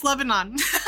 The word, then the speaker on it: Lebanon: Meckley's Brooms from LeBron. – Oh, I'm pronouncing that right. – LeBron, Pennsylvania. Did I say that town name correct too? Hello. – No, it's Lebanon.